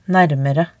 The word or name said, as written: naermere